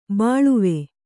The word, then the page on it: ಬಾಳುವೆ: bāḷuve